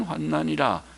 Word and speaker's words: 환난: 이는, 원문의 이는 우리의 잠시 받는 환난에 경한 것, 가벼운 환난이라. 환난에 경한 것이란 말은 가벼운 환난, 환난은 발음을 환난 그렇습니다. 환란이라는 단어는 또 있습니다. 란이라는 단어.